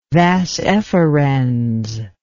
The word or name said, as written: vas efferens